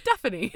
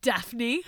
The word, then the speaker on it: Daphne: Did you just add a syllable? Daphne. Like Daffodil? – Or Stephanie? – Daphne. – All right, Daphne.